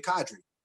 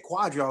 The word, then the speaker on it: Kadri: They would have a tough time pronouncing Kadri. There's no U in my name. It's just simply Kadri. And people would say Quadri all the time.